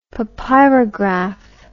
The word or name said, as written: papyrograph